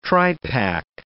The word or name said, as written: tripack